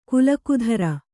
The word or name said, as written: ಕೀಲುಕುದುರೆ